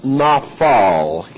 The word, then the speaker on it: naphal: And the verb from which this word Nephilim comes, the noun, is a verb pronounced naphal, N-A-P-H-A-L, looks like that, naphal.